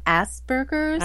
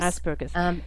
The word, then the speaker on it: Asperger's: And of course, Asperger's, is it Asperger's? Is that how you pronounce it? – It's Asperger's. – Asperger's. – A-S-P-E-R-G-E-R-S.